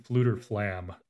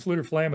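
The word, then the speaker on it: Fflewddur Fflam: Jetzer mentioned Fluter Flam, which is how I pronounce it. It's got multiple F's in it.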